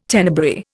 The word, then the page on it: Tenebrae: Indian